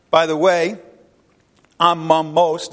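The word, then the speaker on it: amamos (ἄμωμος): It's A-M-O-M-O-S in the English, but it's pronounced amamos, and that's the Greek for it.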